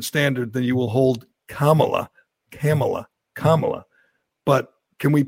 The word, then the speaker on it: Kamala: that will serve you and your fellow um, hosts on Fox her name is pronounced comma like the punctuation mark Look, kamala okay, okay.